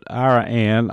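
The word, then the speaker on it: Iraan: It's spelled like Iran, but Iran, I believe, is the way it's pronounced.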